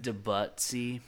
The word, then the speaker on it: Debussy: I don't know how you pronounce that name, but it's French. I know that. – Debussy. – Yeah, there you go.